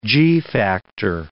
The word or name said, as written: g-factor